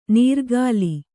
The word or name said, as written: ನೀರ್ಗಾಲಿ